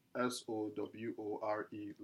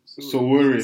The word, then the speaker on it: Sowore: S O W O R E.